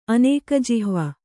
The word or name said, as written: ಅನೇಕಜಿಹ್ವ